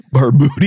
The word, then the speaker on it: Barbuti: Barbudi. – Barbudi. Yeah. It's spelled B-A-R-B-U-T-I.